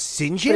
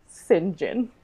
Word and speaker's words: St. John: First name is spelled St. John. – But it is pronounced St. John? – It is pronounced St. John. – And they make a point of telling us that in the book? – No, but everyone that you will take a class from will make a point to let you know it's pronounced St. John.